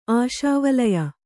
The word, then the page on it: ಆಶಾವಲಯ: kannada